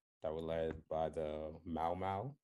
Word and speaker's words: Mau Mau: Did I pronounce that correctly? – Yep. – All right, cool. – Yeah. You have to separate it like Mau Mau. – Oh, Mau. Oh, there's a pause. Mau Mau.